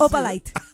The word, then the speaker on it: Opalite: No, si se pronuncia así, ni siquiera se pronuncia así. – Opalite. – Es Opalite, pero.